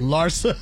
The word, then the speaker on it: Larsa: Larsa. That's how you say it.